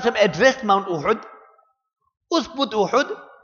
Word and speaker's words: Uhud: Many people pronounce it as Uhud, it is Uhud.